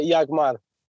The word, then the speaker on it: Yagmar: Uh, e sim, estava certo, é, é assim que se pronuncia é Iagmar.